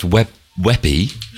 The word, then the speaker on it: WebP: Which we know is pronounced Webby. – Webby, isn't it? Yeah. But it's spelt Webp.